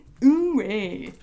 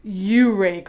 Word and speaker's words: Ouray: Um, so Donna lives in what I would have called Ouray Uray, Colorado, but it's pronounced Uray, Colorado.